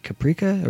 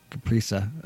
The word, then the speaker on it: Caprica: Um, is it Caprica or Caprisa? Uh, I think it's Caprica. That's how it's pronounced.